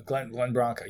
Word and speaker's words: Glenn Branca: So I had read that Kevin Shields was a big fan of Glenn Branca, is that how you say his name? – Glenn Branca, yes.